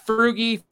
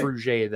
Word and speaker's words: Frugé: Well, she's Frugie, Frugé. They said either one. I talked to his mom. I was like, how do you pronounce this? And he said, it doesn't matter. So I think okay. It's Frugé, though.